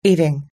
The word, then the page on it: eating: American English